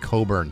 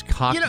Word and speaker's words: Cockburn: It's actually pronounced Coburn. – Why is it written Cockburn? – Because he doesn't want to be known as Cockburn. – You know, you're like, and he calls himself Coburn.